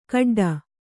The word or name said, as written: ಗಿಡ್ಡಿ